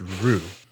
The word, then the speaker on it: Ryu: Apparently, it's just Ru. – So, in Japan, there's actually one syllable to pronounce this word, and it would be Ryu, all in one go.